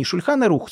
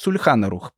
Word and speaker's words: Шульхан Арух: Так и было написано в деле, не «Шульхан Арух», «Сульхан Арух». Я думаю, что Станисов Костинский уже сам забыл, как читается на иврите, но не суть важно.